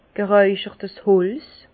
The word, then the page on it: gereicherts Holz: Bas Rhin; Herrlisheim